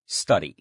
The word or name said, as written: study